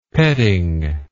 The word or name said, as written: petting